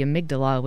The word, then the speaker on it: amygdala: It's the most direct line or the quickest way to get to the amygdala, which is the emotional center of the brain, is through the sense of smell. Did I say that right? – Yeah, no, precisely.